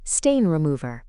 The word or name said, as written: stain remover